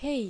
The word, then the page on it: Hei: Japanese